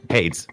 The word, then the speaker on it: Hades: No, it's Hades. Yeah. One syllable.